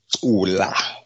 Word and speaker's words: cula: So today's isiXhosa word is cula. Cula. It's spelled C-U-L-A.